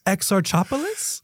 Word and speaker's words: Exarchopoulos: Exarchopoulos? – Why you make it sound so easily?